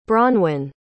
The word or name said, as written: bronwen